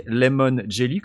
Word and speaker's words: Lemon Jelly: Alors, il faut bien le prononcer Lemon Jelly, comme je de citron »,